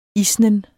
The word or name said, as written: isnen